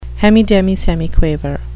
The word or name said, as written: hemidemisemiquaver